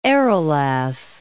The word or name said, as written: Arelas